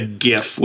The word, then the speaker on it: GIF: And let's talk about the timeline of the GIF with a hard G.